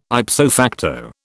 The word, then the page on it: ipso facto: British English